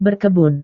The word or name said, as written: berkebun